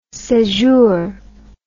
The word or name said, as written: sejour